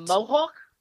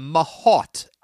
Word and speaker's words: mahout: A mohawk? – A mahout. M-A-H-O-U-T. It's pronounced mahout.